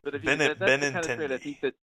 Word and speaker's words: Benintendi: How do you say that guy's name? – Benintendi. – Thank you.